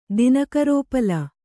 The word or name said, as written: ದಿನಕರೋಪಲ